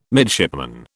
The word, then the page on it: midshipman: British English